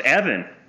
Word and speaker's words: Even: Especially given that, if you were to believe the narrator in Clone Wars, it is pronounced Evan.